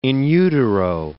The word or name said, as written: in utero